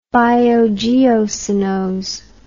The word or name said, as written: biogeocenose